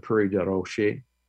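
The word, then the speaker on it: Prairie du Rocher: So, you have Prairie de Roche, Illinois. Some people call it, Prairie or Prairie de Rocher. But it's Prairie de Rocher is in French,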